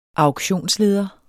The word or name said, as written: auktionsleder